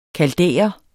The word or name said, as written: kaldæer